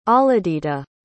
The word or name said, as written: ahladita